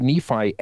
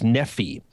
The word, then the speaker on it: Nephi: the R sound would have been replaced by a long E sound, And so it would have been pronounced most likely as Nephi. Now, we as 21st century Americans and, of course, 20th century Mormons have pronounced this as Nephi, but sometimes the I on the end of Nephi can be pronounced as an E, as a long E.